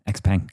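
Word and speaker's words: XPeng: X-Peng. X-Peng, I think it's pronounced.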